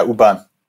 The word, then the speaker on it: U-Bahn: Yeah, U-Bahn.